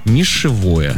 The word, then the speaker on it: нишевые: Но один молодой человек снова зашел в социальные сети и в интернет и узнал, что нишевые неправильно говорить, потому что правильно говорить как? – Как? – Нишевое.